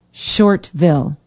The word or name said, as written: Shortville